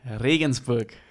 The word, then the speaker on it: Regensburg: I grew up in Bavaria, in Regensburg. So that's the city. It's okay. It's kind of say that again. – Regensburg.